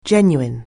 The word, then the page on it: genuine: anglais